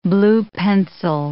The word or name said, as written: blue-pencil